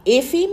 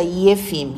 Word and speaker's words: Efim: Efim e-, wird es ausgesprochen. Es wird auch Efim ausgesprochen, aber wie ich von meinem Vater gehört habe, er war immer Efim. Efim. Mhm. Efim. Ja. Efim. Ja. Das Russische wird ja Efim ausgesprochen oder Jefim. Jefim.